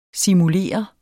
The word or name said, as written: simulere